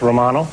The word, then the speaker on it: Romano: Does a Ray Romano Romano, pronounced Romano? – Romano.